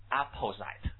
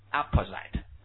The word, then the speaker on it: apposite: No，这个念作 apposite， apposite 啊，念作 apposite， right 适当的啊，就说意见比较中肯，就是意思 apposite。